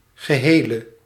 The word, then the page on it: total: [tɔ.tal]; France